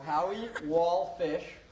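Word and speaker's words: Howie Walfish: Howie Wallfish. – Yes. – W-A-L-F is in Frank, I-S-H.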